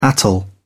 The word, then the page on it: atoll: British English